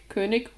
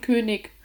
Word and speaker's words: König: König, mit, nee, König, König, Honig. Ich glaube, ich sage Honig und König. – Ah ja, richtig ist jeweils CH.